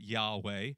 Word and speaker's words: Yahweh: And in Scripture, it's spelled with these 4 letters, YHWH, but we pronounce it Yahweh.